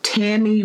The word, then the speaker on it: Tami: Now, if you're looking for me on social media, I am at Tammy Roman. That's T-A-M-I. My pet peeve is you spell my goddamn name wrong. So T-A-M-I Roman, or at Official Bonnet Chronicles on IG, at Tammy Roman on Twitter, and Tammy Roman fan page on Facebook.